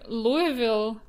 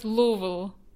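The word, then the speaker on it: Louisville: В общем, а, произносится как Луивилл, а местные называют Лувел. – Угу. Как-то так. Так вот, хорошо, Луивилл. Расскажи, почему ты сейчас находишься в городе Луивилл, штата Кентукки?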